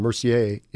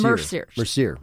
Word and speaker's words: Mercier: And they have some interesting, something called an Insider Winter Harvest, a sparkling apple wine that's made from fruit from Mercier in Blue Ridge. Did I pronounce that right? – No, it's Mercier.